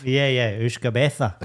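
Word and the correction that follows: beatha: So, I don't want to correct your pronunciation, but that was wrong. – What is it? – Beha. – Beha, so it is, yeah.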